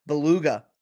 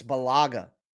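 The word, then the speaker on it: Bulaga: I would say Beluga, like the whale, but it's Belaga.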